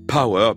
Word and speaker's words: P.O.W.A: Sur la vignette qui accompagne sa dernière chanson parue qu'on vient d'entendre, Power, P.O.W.A, comme on peut prononcer Power en anglais, il y a écrit Maya j'unis les gens depuis 2003.